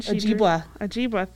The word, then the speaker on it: Ojibwe: I am gonna butcher this. Ojibwe reservation. – Ojibwa, thank you. See, I just need to talk to you beforehand.